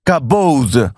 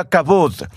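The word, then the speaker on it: kabod (כבוד): es la palabra hebrea kabod. A veces se pronuncia kabod.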